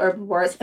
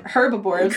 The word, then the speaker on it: herbivores: I'm not calling them herbivores. – Correct. – No H. No herbs. – The H is nowhere to be seen, to be heard.